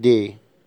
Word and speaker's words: des: اما دي اخرها اس، لا تنطق الاس، زي دي ستيلو، اقلام.